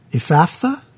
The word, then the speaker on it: Ephphatha: And looking up to heaven, he sighed and said to him, 'Ephphatha?'" Is that what you would say? Ephphatha? Is that how you pronounce that? – Ephphatha. – Ephphatha.